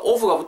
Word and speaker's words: of: 그리고 care 하고 또 of가 붙으면서 care of, care of, care of 이렇게까지 붙게 됩니다. of 발음하실 때 make sure you don't pronounce this word of because there's no b sound. There's f sound.